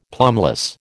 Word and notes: plumbless: American English

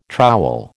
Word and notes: trowel: American English

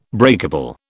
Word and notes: breakable: British English

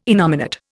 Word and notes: innominate: Indian

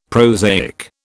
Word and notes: prosaic: British English

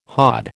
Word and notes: hod: American English

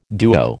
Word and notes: duo: American English

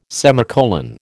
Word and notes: semicolon: American English

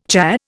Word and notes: cha: Indian

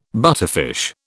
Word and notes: butterfish: British English

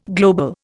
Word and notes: global: Indian

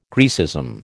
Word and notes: Graecism: American English

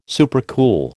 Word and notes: supercool: American English

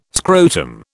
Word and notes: scrotum: British English

